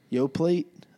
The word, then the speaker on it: Yoplait: It's Y-O-P-L-A-I-T. – I know the spelling. I know the spelling, but I never hear people pronounce the T. Well, ever.